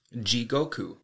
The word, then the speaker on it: Jigoku: Today's Lessons is going to be the Japanese word for hell, and that is Jigoku. Jigoku is spelled J-I-G-O-K-U.